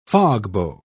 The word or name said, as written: fogbow